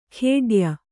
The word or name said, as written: ಖೇಡ್ಯ